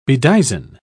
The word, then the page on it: bedizen: British English